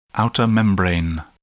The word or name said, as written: outer membrane